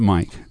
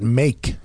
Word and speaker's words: Mayk: It's a Dutch spelling of Mike, M-A-Y-K. So cool. – M-A-Y-K? – Yeah, you pronounce it Mike. – I would pronounce that make. – Well, of course, until someone corrected you and said, Mike.